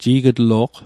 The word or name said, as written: ji'gitlo'q